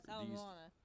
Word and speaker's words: Ala Moana: Yes, Ala Moana.